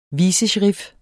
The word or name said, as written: vicesherif